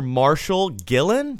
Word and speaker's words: Marshal Gillen: Well, we have a very special guest today, Mr. Marshal Gillen.